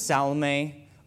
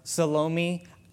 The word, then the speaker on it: Salome: And this is Salome or Salome. I don't like saying Salome because I want to say salami. And I'm going to get hungry, so it's pronounced either way, so I might say one or the other.